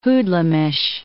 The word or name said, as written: hoodlumish